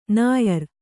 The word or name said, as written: ನ್ಯಾರಿ